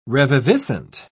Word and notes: reviviscent: British English

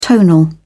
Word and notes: tonal: British English

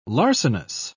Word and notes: larcenous: British English

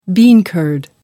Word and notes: bean curd: American English

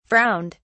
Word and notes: frowned: British English

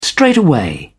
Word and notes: straightaway: British English

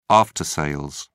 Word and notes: after sales: British English

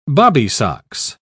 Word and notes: bobbysocks: British English

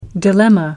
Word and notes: dilemma: American English

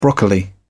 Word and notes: broccoli: British English

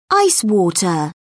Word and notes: ice water: British English

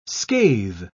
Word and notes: scathe: British English

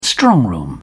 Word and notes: strongroom: British English

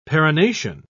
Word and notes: perennation: British English

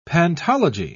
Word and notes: pantology: British English

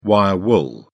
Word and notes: wire wool: British English

